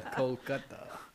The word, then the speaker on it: Kolkata: He met his wife Shivani Chatterjee, a fluent Bengali speaker, whose family hails from Calcutta, Kolkata, if that's the proper pronunciation. – Sanchali would hear, yeah. – Kolkata.